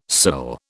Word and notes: cill: British English

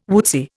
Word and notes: woodsy: Indian

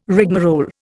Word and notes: rigmarole: Indian

